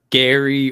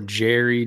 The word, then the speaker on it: Gerry: It's Gary or Jerry, depending on how you pronounce it. Parks and Recreation affiliation. – Yeah. G-E-R-R-Y. We have pronounced it multiple ways on this podcast.